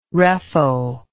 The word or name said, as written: reffo